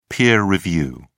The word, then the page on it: peer review: British English